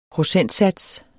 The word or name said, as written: procentsats